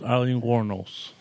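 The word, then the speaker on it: Aileen Wuornos: Well, anyway, yes, Eileen Warnos.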